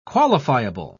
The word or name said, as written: qualifiable